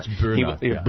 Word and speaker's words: Boehner: We're going to find the real, Boehner, pronunciation one day. – It's Boehner.